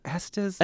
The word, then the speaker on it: Estes: The Washoe Club in Virginia City, and of course the Stanley Hotel in Estes Park, Colorado. I can never say. Is it Estes? – I think so.